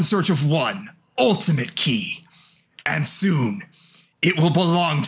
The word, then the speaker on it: chi: In the written dialogue, it's written as the Greek letter chi, which they're pronouncing key.